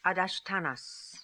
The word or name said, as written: Adashtanas